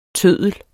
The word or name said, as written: tøddel